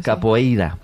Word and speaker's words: capoeira: Pues todos sabemos lo que es la copeira, ¿verdad? – Capoeira. – Qué mal pronuncio últimamente.